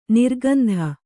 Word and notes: ನಿರ್ಗಂಧ: nirgandha

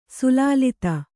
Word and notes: ಸಲ್ಲಲಿತ: sallalita; Kannada

